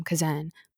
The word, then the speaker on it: Kazan: And I've been saying her name wrong the whole time. Kazan. So sorry.